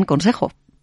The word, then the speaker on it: concejo: Y ahora le llega el turno al concejo con C, que en muchos sitios se pronuncia también consejo.